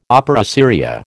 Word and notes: opera seria: American English

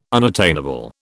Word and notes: unattainable: British English

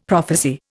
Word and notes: prophecy: Indian

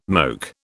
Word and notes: moke: British English

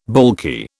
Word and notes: baulky: British English

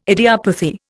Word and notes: idiopathy: Indian

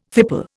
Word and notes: fipple: Indian

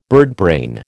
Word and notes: birdbrain: American English

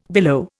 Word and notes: billow: Indian

